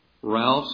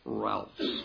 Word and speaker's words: Rahlfs: In Septuagint, Routh's is spelled R-A-H-L-F-S, Routh's.